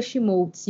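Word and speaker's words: Schmolz: Tanto na Twitch fazendo live, quanto no Instagram, quanto no Twitter, como arroba Fernanda Schmoltz. E o meu sobrenome se escreve S-C-H-M de Mickey O-L-Z.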